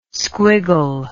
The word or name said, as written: squiggle